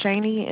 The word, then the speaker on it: Shaney: My name is Shani, and it's spelled S-H-A-N-E-Y.